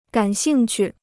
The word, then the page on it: 感兴趣: gǎn xìng qù